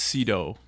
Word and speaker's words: Sydow: I believe it's Cedo rhymes with Greedo. – I wish it did rhyme with Greedo. But I think it does. – Well, I think it's Max von Sydow, but it's all right.